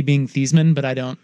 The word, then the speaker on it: Theismann: What I heard is that his name was Joe Theismann, and they changed his name to Theismann to rhyme with Heisman.